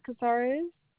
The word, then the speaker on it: Cazares: Nita Cazares.